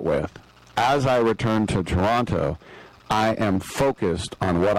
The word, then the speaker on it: Toronto: And by the way, I do realize that people in Ontario don't pronounce the last T, that it's Toronto.